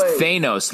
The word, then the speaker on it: Thanos: No, it's Thanos, like anus. Thanos. – No. – Thanos. Well, Thanos, like anus.